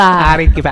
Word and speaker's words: Arequipa: Oh, it was Arequipa. – Arequipa.